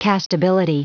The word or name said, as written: castability